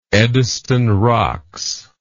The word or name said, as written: Eddystone Rocks